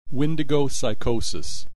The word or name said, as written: Windigo psychosis